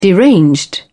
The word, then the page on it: deranged: British English